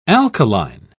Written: alkaline

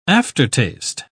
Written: aftertaste

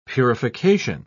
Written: purification

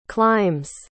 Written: clime's

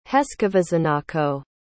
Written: heskovizenako

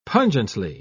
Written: pungently

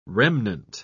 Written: remnant

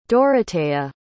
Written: Doroteia

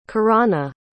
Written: kirana

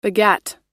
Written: begat

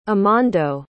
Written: amando

